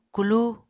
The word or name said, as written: kulu